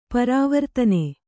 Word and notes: ಪರವರ್ತನ: Kannada

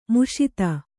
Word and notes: ಮುಷಿತ: muṣita

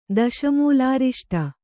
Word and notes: ದಶಮೂಲಾರಿಷ್ಟ: Kannada

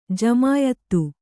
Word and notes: ಜಮಿಯಿತ್ತು: jamiyittu

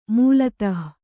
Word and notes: ಮೂಲತಃ: mūlatah

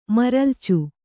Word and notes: ಮರಲ್ಚು: maralcu

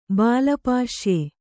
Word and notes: ಬಾಲಪಾಶ್ಯೆ: bāla pāśye

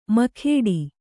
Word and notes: ಮುಕುಟ: kannada

